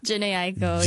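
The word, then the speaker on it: Jhené Aiko: Janae Aiko. – Janae Aiko.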